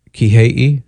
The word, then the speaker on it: Kihei: All right, Kihei. Kihei. – Perfection.